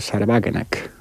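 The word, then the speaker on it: Sahra Wagenknecht: es el de Sarwagenack, o como se diga en alemán, ¿no? – Está bien pronunciado, Sí.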